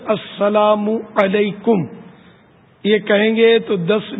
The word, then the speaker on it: السلام علیکم: تو یہ صحیح تلفظ اس کا یہ السلام علیکم میم پر پیش ہے,